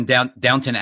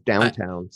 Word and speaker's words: Downton: Is other than Downton Abbey. Did I say it right? – Oh, Downton Abbey? Thank you for finally including Downton Abbey in my Peacock. It usually says downtown, so.